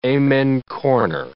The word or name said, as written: amen corner